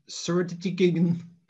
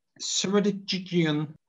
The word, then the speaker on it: Ceredigion: It's called the Ceredigion. I tell you, I pronounced that properly. Ceredigion Coast Path. I couldn't get my tongue around that word. – I think you've nailed it, yeah.